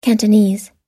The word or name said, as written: cantonese